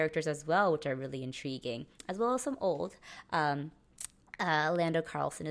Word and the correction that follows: Calrissian: is back, and Lando Carlissian. – Calrissian, I can't say his name, is back.